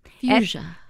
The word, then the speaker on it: Fusia: Fusia.